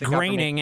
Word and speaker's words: Groening: You know without a shadow of a doubt that Matt Groening is not part of a secret society. – It's pronounced graining, and yeah, I'm pretty sure.